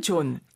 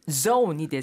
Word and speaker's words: zone: 근데 이게 사실 zone이 돼서 그 여기 좀 예민하신 분들은 아우 저거 Z인데 뭐 자꾸 J처럼 발음하나 이런 경우 있거든요. 제가 그래요. – 우리나라 네. 사람들끼리는 괜찮아요. – 네. – 존, 존, right? – 네. – But when you talk in English, try to pronounce it with the Z, okay? – Z.